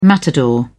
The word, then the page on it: matador: British English